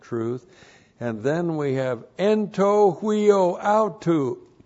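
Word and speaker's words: Anto huio autu (ἐν τῷ υἱῷ αὐτοῦ): En to huio auto. And that's E-N-T. This is the dative case of the article, T long O. And then H-U-I long O. And then A-U-T-O-U. Anto huio autu.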